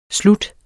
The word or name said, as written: slut